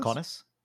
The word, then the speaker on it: Kaunas: Kaunas.